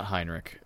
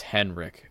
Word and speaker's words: Henrik: Oh, it's not Heinrich. It's Henrik.